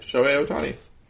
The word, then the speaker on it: Shohei Ohtani: named Shohei Ohtani. – No, you got it right. Shohei Ohtani.